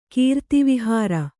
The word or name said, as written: ಕೀರ್ತಿವಿಹಾರ